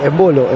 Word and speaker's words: Embolo: Sí, Embolo, en fin, finalmente el acento en la primera O.